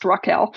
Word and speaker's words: Rachel: And when he met my main character, it's spelled Rachel, but technically pronounced Raquel, but from the first book. I still call her Rachel, but it's a minor thing.